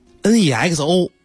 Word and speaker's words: Nexo: 怎么读 NEXO？然后我特别找了外媒的媒体老师念的 Nexo。